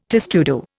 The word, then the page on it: testudo: Indian